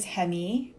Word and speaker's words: Hemi: So hey everyone, my name is Hemi, pronounced like the Dodge Hemi engine, if that helps.